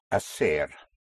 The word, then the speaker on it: hacer: To do or to make is hacer. Spelled H-A-C-E-R. Again, the H is not pronounced, so you have hacer, to do.